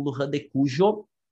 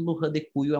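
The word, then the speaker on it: Luján de Cuyo: depende da área, por exemplo, vocês podem ouvir a expressão como Lurra de Cuyo ou vocês podem ouvir como Lujan de Cujo, certo? Aí depende qual é a cultura lá que tá, ou a área da onde vem a pessoa. As duas pronúncias vocês vão ouvir, tá? Normalmente Lujan de Cujo é o mais, é o mais comum, assim como Mendoza é o mais comum na Argentina quando fala da cidade.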